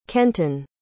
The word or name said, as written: kenton